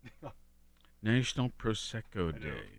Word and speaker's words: Prosecco: National Prosecco Day, is that how you say it? – Mm-hmm.